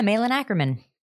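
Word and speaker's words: Malin Akerman: It's, how do you pronounce her name? Malin Ackerman? – Oh, yeah. Malin Ackerman. – Yeah, yeah, Malin.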